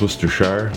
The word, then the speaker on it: Worcestershire: Oh, that's how they say it? Not Worcestershire? No, it's Worcestershire. – Well, have you ever seen the YouTube video? – No. – It's pronounced no thanks. – Yeah. Because it's Worcester, Massachusetts, but then it's not that for the sauce.